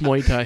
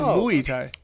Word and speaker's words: Muay Thai: How to pronounce Muay Thai. – Yes. Muay Thai. No, right. It's pronounced Muay Thai. – He said Muay Thai. – That's how it's pronounced.